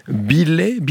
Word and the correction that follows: Belly: Belly. – Belly, pardon. Il s'appelle Borondo, l'album.